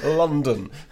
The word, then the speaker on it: London: Spelt with an O, pronounced with a U. London.